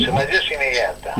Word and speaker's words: Tsunayoshi Megata: Pero antes que nada, eh, le voy a pedir que nos haga una pequeña introducción este, contándonos quién fue, a ver si lo digo bien, Tsunayoshi Megata. ¿Es así? Sunayoshi Megata. Muy bien.